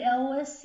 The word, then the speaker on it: Elwes: And I'm saying Larry Gordon because I can never pronounce Carrie Elwes. Is that? – I've always said Elwes. Yeah, Carrie Elwes. – Yeah, that's probably better than my pronunciation.